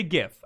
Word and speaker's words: GIF: That one's weird because GIF stands for graphical image format, but the creator of it pronounces it as JIF. – Right, well, the creators in this case pronounce it as Mojang. So it's one of those. Like, I say GIF.